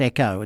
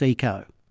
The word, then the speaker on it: eco: I was just thinking, last week when you talked about the fashion, and you just talked about it then, I don't believe it's pronounced echo. It's eco.